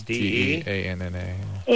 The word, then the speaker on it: Deanna: One's D E A N N A.